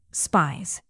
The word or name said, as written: spies